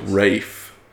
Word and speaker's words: Ralph: I think it's Fiennes, but I think it's Rafe. – Oh, really? – Is how they pronounce it. It's spelled Ralph.